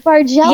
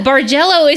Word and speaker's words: Bargello: Bargello? Is that how you say it? – Yes, Bargello. – Yeah, Bargello is so fun.